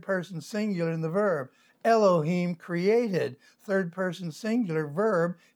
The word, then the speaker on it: Elohim: So the word Eloah means God, and Elohim, the I am, pronounced E, long E, Elohim, is a plural for God.